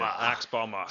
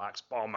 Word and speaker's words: axe bomber: Axe bomber. – Sorry. – That's how it's pronounced as well. Axe bomber.